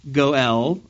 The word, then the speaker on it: goel: So this is just real quickly the law of the kinsman redeemer, Gaal in Hebrew, but for some reason in English they always pronounce it as Goel. I don't know how it ever became Goel, but I'd like to fit in, so I'll stick with that.